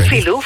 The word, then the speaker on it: Filou: Filou, zeg ik het goed zo, Filou? – Ja, okay. Ja, Filou.